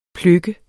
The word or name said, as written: pløk